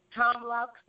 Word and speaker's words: Tom Loucks: Tom Lauk is the multimedia coordinator for departments within the city of Wisconsin Rapids and its community media television station.